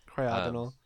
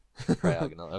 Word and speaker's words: Cryogonal: Am I pronouncing, is the emphasis in the correct place now? – What I would say. – You need to know these things now. – Cryogonal. – Cryogonal, okay.